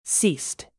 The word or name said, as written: ceased